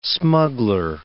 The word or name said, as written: smuggler